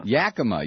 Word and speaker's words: Yakima: Yakima.